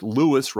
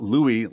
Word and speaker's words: Louisville: First off, it's pronounced Louis, rhyming with sue us, not Louis like a long-dead French king.